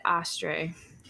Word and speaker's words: Oesterreich: In Dolly's early 20s, she met and eventually married a man named Fred Astray, which is how I found his name pronounced on the internet. So don't get mad if that's not right, because it's spelled O-E-S-T-E-R-R-E-I-C-H.